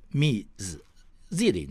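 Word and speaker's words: Ms: 后面呢要接姓氏或者全名，比如说叫做 Miss Jenny Green 啊，Miss Jenny Green 啊 Miss Chen，Miss Chen 哈，这个 Miss M S 这个字呢，它发音是 Miss Z 啊，Miss 这个称呼呢是美国女权运动 Sheila Michaels 她创造的。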